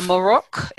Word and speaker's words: Amarok: Amarok, is that how it's pronounced? Amarok Adventures. – And exactly.